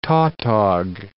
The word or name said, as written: tautog